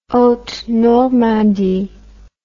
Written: haute normandie

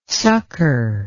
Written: sukkur

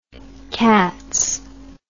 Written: cats

